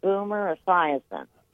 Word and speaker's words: Esiason: But dad used to, there was this football player called Boomer Esiason. – Mm-hmm. I know. – Right? Am I pronouncing that right? – Boomer, yep, you are.